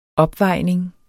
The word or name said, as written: opvejning